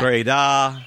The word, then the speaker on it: Breda: I finally am pronouncing that correctly. – Oh, really? It's Breda. – Yeah. – Breda. – Yeah, Breda or something. – Breda. Breda. – It's not Breda, which is how I'd been saying it up until that point.